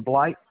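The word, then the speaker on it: Bleidt: Aaron Christopher Blight.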